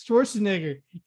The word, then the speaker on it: Schwarzenegger: It's Schwarzenegger. – Yeah. That's a long A.